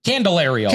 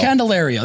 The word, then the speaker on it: Candelario: Candelario. – Candelario.